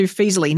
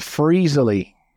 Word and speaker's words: Feezily: So some people mispronounce and call it Freezily. I don't know. Oh. They add an R in there.